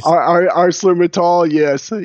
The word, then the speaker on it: ArcelorMittal: With over 20 years of management experience in international companies, he worked for Intel Semiconductor, Valorec, and how do you say this? ArcelorMittal? You've worked for some interesting companies. – ArcelorMittal, yes. Yes.